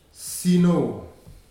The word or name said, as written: Sino